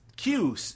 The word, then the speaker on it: Khu: Uh, yes, Q uh, K H U is how this uh, person's name is spelled.